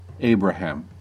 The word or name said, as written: Abraham